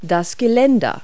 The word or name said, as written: handrail